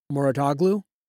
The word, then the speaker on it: Mouratoglou: And I don't know how you feel about this, but that, correct me if I'm pronouncing his name wrong, but the Patrick Mouratoglou, is that? – Yeah.